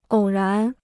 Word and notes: 偶然: ǒu rán

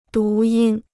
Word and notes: 读音: dú yīn

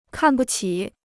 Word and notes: 看不起: kàn bu qǐ

